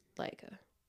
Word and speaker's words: Laika: I think it's pronounced Laika. L-A-I-K-A.